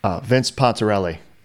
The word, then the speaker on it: Pontarelli: And software by Lyman Sheets Jr. How would you say that? Vince Pontarelli. – Vince Pontarelli. It's fine. – Pontarelli. – You don't have to make it insanely Italian.